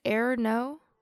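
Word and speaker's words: errno: Is it error no? – Yeah, that's how I say it. – Okay. Error no, epipe.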